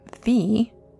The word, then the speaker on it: phi: The golden ratio, also known as phi, and or if you want to get technical about it, phi, the correct way to pronounce it because it comes from the Greek, is said to be a ratio of 1 to 1.6.